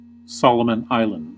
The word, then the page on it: Solomon Islands: American English